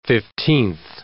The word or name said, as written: fifteenth